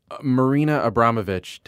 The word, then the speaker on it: Marina Abramovich: Oh yeah, and I mean, hey, look, Marina Abramovich, did I say her name right? – Yeah.